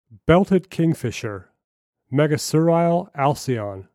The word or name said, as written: Belted Kingfisher